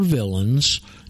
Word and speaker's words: villein: So in Black's, it's spelled V-I-L-L-E-I-N and pronounced villain.